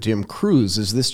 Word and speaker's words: Qreuz: So I have this other show called E-commerce Undercover, and we had this guy from Germany on, and the name of his company is Q-R-E-U-Z, and he pronounced it Cruise.